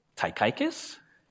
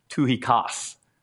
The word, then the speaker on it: Tychicus: You're like, Tychicus? Tychicus? Right? You're right? Because right away we're doing it phonetically through English, right? And that is actually pronounced Tuhikas. How many of you knew that?